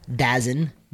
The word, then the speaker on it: DAZN: It's spelled DAZN, but it's pronounced DAZN.